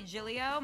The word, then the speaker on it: Giglio: My Twitter and Instagram are at Gracie Giglio. My last name is spelled G-I-G-L-I-O.